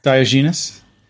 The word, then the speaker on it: Diogenes: The lantern and the old man and the dog all point to a representation of this ancient philosopher called Diogenes, I think. I don't know if I'm pronouncing that correctly, or Diogenes. – Diogenes.